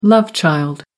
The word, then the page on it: love child: American English